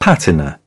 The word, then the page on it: patina: British English; t